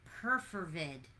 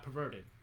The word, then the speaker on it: perfervid: Your word of the day is perfervid. – Can you spell it? – P-E-R-F-E-R-V-I-D. Perfervid. – Perfervid? – Perfervid. Perfervid? – Perfervid? – I guess so. Perfervid. – Not perverted. – Not perverted. Perfervid. They probably did that on purpose just to, like, screw you up.